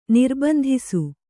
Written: ನಿರ್ಬಂಧಿಸು

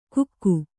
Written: ಗುಕ್ಕು

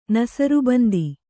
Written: ನಸರ್ಬಂದಿ

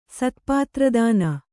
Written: ಸ್ಥಪತಿರತ್ನ

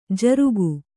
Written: ಝುರುಕಿ

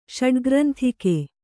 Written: ಷಡ್ಗ್ರಂಥಿಕೆ

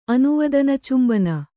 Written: ಅನುವದನಚುಂಬನ

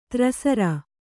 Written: ಧಾರಾಸಾರ

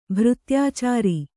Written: ಭೃತ್ಯಾಚಾರಿ

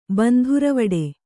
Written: ಬಂಧುರವಿಡಿ

